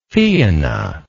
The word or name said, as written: Fianna